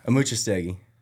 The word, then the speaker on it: Amuchastegui: Amuchastegui. It's standard spelling. – Amuchastegui. Oh, when you say it, it looks good. – It's phonetically correct.